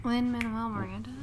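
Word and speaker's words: Lin-Manuel Miranda: Lin Manuel Miranda.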